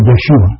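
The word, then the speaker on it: Yeshua (ישוע): In Hebrew, you pronounce it Yeshua or Yehoshua.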